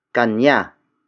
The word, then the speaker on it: kanya: The last locative pronoun for this lesson is kanya. Kanya. Kanya. Kanya is spelled as K-A-N-Y-A.